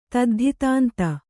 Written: ತದ್ಧಿತಾಂತ

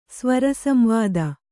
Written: ಸ್ವರಸಂವಾದ

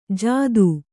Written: ಜಾದೂ

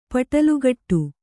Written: ಪಟಲುಗಟ್ಟು

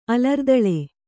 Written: ಅಲರ್ದಳೆ

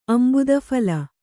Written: ಅಂಬುದಫಲ

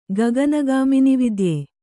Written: ಗಗನಗಾಮಿನಿವಿದ್ಯೆ

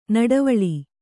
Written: ನಡುವಳಿ

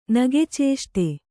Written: ನಗೆಚೇಷ್ಟೆ

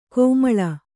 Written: ಕಮ್ಮಾಳ

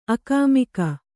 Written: ಆಗಾಮಿಕ

